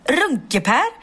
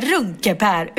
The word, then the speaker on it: Runkeeper: Och då insåg jag att Runkeper uttalas inte Runkeper utan Runkeper. Runkeper, Runkeper.